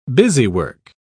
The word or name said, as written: busywork